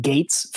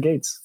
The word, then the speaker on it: Gueits: And so the only, like, if you look at my last name, the only way that you can get Gates from it is if you pronounce it in Spanish. Like, the G-U-A makes the G noise, the E-I together makes the A noise, and then you get the T-S. So that's how we got Gates.